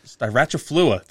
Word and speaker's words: styraciflua: and that is liquid ambar styraciflua.